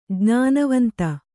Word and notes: ಜ್ಞಾನವಂತ: kannada

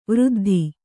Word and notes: ವೃದ್ಧೆ: Kannada

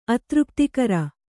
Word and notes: ಅತೃಪ್ತಿಕರ: kannada

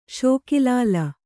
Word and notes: ಷೋಕಿಲಾಲ: ṣōki lāla

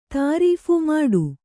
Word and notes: ಮುಟ್ಟ: kannada

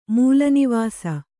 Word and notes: ಮೂಲನಿವಾಸಿ: mūla nivāsi

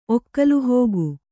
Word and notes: ಒಕ್ಕಲುಹೋಗು: kannada

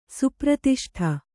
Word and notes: ಸುಪ್ರತಿಷ್ಠೆ: Kannada

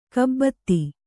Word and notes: ಕೈಬೆತ್ತ: Kannada

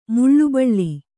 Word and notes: ಮೌಲಬಲ: maula bala

